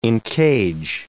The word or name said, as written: encage